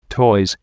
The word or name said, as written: toys